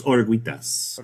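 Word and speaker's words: Oruguitas: How do you say that? Dos Orguitas. – Orguitas.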